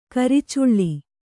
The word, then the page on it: ಕರಿಚುಳ್ಳಿ: karicuḷḷi